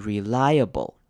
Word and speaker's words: reliable: 首先看到 I 我们会念成长母音 I 的声音 rely，后面的 A 呢我们会念成轻音的声音，所以这个字合起来是念成 reliable。